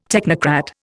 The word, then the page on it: technocrat: Indian